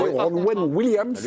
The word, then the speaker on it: Ronwen Williams: La réaction du gardien Ronan Williams, je ne sais pas si je l'ai bien prononcé. Ronwen Williams. – Lui, c'est son match. – Il est bien prononcé, Néné.